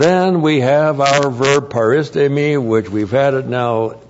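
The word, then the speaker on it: paristemi (παρίστημι): P-A-R-I-S-T, long E-M-I, paristemi.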